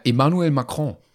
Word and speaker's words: Emmanuel Macron: Emmanuel Macron.